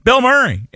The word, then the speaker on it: Bil Muri: It is B-I-L-M-U-R-I. Pronounced the exact same way. Bill Murray.